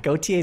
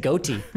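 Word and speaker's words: Gotye: Gautier is how you pronounce it. I was saying goatee.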